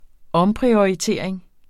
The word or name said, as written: omprioritering